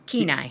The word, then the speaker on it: Kenai: You grew up mostly on the Kenai, is that how you pronounce it? – Kenai.